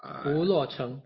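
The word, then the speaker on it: Pu Luo Chung (蒲罗中): And basically they describe an island at the exact same location, but they call it Pu Luo Chung. Am I pronouncing it right? Pu Luo Chung. – Pu Luo Chung. – Yeah.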